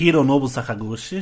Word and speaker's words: Hironobu Sakaguchi: O Hironobu Sakaguchi. Hironobu Sakaguchi.